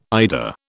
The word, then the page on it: eider: British English